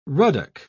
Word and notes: ruddock: British English

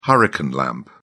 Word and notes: hurricane lamp: British English

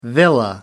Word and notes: villa: American English

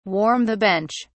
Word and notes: warm the bench: British English

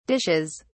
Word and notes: dishes: British English

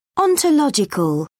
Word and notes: ontological: British English

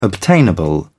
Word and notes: obtainable: British English

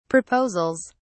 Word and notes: proposals: British English

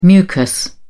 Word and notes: mucus: British English

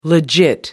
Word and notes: legit: American English

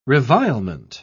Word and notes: revilement: British English

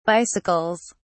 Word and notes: bicycles: British English

